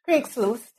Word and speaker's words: Kriegslust: Kriegslust, am I pronouncing that correctly? – Kriegslust, yeah. I think so.